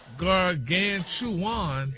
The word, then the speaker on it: gargantuan: I sound like you missed a syllable there, sir. – Oh, gargantuan. – No. – No, sir. – Maybe you should just make some more videos with you with different clothes on with Stephanie Mills music like you've been doing. – Look at the breakdown of the word. – It's pronounced gargantuan. – Yeah, now you're in the neighborhood.